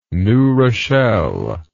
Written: new rochelle

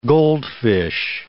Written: goldfish

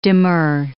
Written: demur

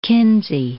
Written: Kinsey

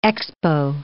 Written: expo

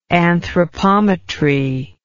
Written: anthropometrist

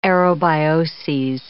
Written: aerobioses

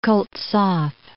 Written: Koltsov

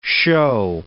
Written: shew

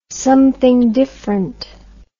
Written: something diffrent

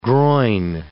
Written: groin

